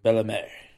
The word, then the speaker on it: Bellemare: I have no idea how to pronounce his name. Belmar. – Pierre Edouard Bellemare.